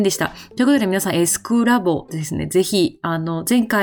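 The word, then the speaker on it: SQlab: でね、確かにアルファベットでは S と Q って書くんですけど、これどうやらですね、正しい読み方としてはドイツ語読みをするらしいんですね。で、正しい発音としては SQ ではなくて S クラボっていう読み方をするようでございました。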